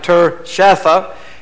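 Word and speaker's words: Tirshatha: And Nehemiah, which is the Tershatha, let me say that right, Tershatha,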